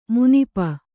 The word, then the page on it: ಮಿನುಪು: minupu